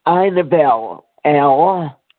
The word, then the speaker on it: Inabelle: My name is actually Inabel L. and I live in Maryland. – Would you spell it, please? – Yes, I-N-A-B-E-L-L-E.